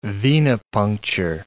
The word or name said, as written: venipuncture